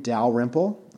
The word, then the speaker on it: Dalrymple: My email at work is my last name, Dalrymple.